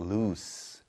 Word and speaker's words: loose: 요거는 lose가 아니고 loose 발음이 납니다.